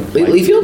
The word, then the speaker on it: Liefeld: There's a great picture of Cable meeting Rob Liefeld. How do you say his name? – Liefeld? – Liefeld.